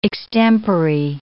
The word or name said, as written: extempore